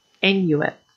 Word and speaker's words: Inuit: Inuit, I believe, is how you pronounce it.